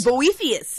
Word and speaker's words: Boethius: Boethius. – Sure. – There's no B. There's no B in that, isn't there? – No, it's Boethius. – Boethius. – Yeah, would you like me to spell it? – Yes, please. – B-O-E-T-H-I-U-S.